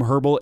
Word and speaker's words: Herbel: That's at Herbal Essences, not spelled like the company. That is spelled like my last name, Herbal, H-E-R-B-E-L, and then Essences.